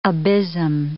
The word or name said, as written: abysm